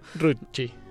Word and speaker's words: Ruzzi: de Rusi, que se escribe R-U-Z-Z-I. – Latina. y latina, exacto. Ruchi. Ah, exacto.